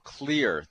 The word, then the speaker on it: Xlear: Well, our special guest today is Nathan Jones, and he is the CEO of Xlear. That's spelled with an X, so it's X L E A R.